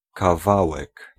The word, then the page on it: morceau: France; [mɔʁ.so]